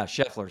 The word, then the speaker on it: Scheffler: And is it Scheffler? Is that how you pronounce it? – Yeah, Scheffler.